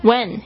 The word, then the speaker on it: When: When 也有人会念成 when，但是呢比较少。 When 可以直接把 h 这个声音省略掉。